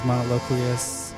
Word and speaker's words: Monoloquious: My name is Monoloquius, M-O-N-O-L-O-Q-U-I-O-U-S.